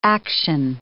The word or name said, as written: action